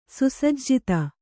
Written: ಸುಸಜ್ಜಿತ

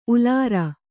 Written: ಉಲಾರ

